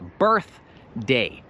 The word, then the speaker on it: birthday: Musisz troszeczkę wystawić język i dmuchać th. Na przykład birthday, birthday. Nie wiem, skąd wzięliście birthday albo birthday.